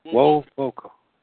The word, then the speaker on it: Wovoka: Wovoka. How do you pronounce that again? I'm sorry. His name. Wovoka.